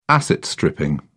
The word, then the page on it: asset-stripping: British English